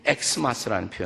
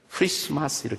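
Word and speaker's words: Xmas: 근데 그 엑스마스라고 읽으시면 안 돼요, 사실은. 그 엑스는 영어의 알파벳의 엑스를 뜻하지 않습니다. 그 엑스라는 단어는 본래 히라보 단어를 말하는 것이에요. 그리스어 단어입니다. 우리가 크리스토스, 예수님을 가리켜서 히란 말로는 크리스토스라는데 그 첫 글자 키 CH 영어로는 근데 그것을 표기할 때 X라고 표기하는 거예요. 크라는 키 크리스토스 크리스토스. 그래서 X라고 썼지만 그것이 영어 알파벳의 X를 뜻하지 않기 때문에 읽을 때 엑스마스라고 읽으시면 안 됩니다. 크리스마스 이렇게 읽으셔야 돼요.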